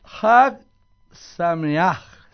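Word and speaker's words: Hag Sameach (חג שמח): Hag Sameach.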